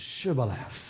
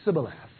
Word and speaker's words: Shibboleth: Because the Ephraimites can't pronounce Shibboleth. They can't pronounce the H. So it would be Sibboleth."